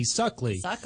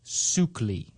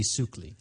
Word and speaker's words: Suckley: Now, as you can imagine, how happy we were to think that the name Daisy Suckley, brought unto us as a gift to the movie gods. But unfortunately, as it turns out, it's actually pronounced Suckley. Daisy Suckley.